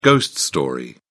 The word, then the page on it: ghost story: British English